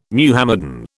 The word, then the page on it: Muhammadan: British English